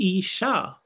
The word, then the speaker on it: Isha: But Isha, with a long A at the end, is the feminine form.